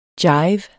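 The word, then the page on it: jive: Danish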